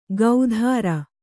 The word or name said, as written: ಗೌಧಾರ